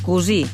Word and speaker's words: cosí: Fixeu-vos, cosí pronunciat amb U segons la procedència, però sempre s'escriu amb O i una S.